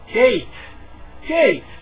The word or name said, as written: gate